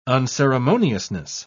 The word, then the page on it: unceremoniousness: British English